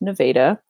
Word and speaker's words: Nevada: spelled like Nevada. Pronounced Nevada, and that's where I grew up. So I was there all the way through high school. – Well, Nevada likes to be called Nevada, I believe, not there's a Nevada.